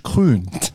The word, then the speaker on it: Krün: Und deswegen gibt's nächstes Jahr am gleichen Wochenende, und wenn ihr da Lust habt mitzumachen, gibt's die Challenge Krün. – Wie schreibt man das? – Mit K-R-Ü-N, weil Rot ja anders geschrieben ist.